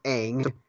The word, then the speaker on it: Aang: And so and also the movie mispronounced a bunch of names that – like it's not Aang, it's Aang. It's not Sokka, it's Sokka.